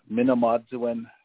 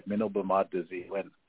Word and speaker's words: Manadzilin: The pronunciation, agency pronunciation was pretty good. It's Manadzilin Health Services. And in our language in Ojibwe, it refers to the good life, Manadzilin.